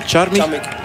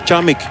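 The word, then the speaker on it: Charmig: Ik ga eventjes, want ik heb dat al langer willen doen, vragen wat de juiste uitspraak is van die Deen, Charmich. What is the right pronunciation of from Anton Charmich? – Charmich. – Charmich. Okay, thank you very much.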